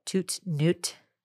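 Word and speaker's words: Toute Nuit: They're called Toot Newt.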